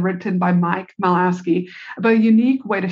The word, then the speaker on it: Michalowicz: I first heard of this concept a few years ago when I heard at an event, when I heard the author, Mike, I can never say his name right, Michalowski, I think, Michalowicz, speak at an event.